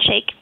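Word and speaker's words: Shaich: which was kind of the thrust of the article in The New Yorker today, with your founder, what is it, Ron Sayich? Is that how you say it? – Ron Shake. – Shake.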